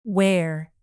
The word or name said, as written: where